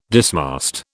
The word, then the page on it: dismast: British English